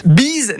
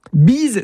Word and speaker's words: Beez: Bees n'a rien à voir avec la bise. Bees s'écrit B-E-E-Z comme freeze.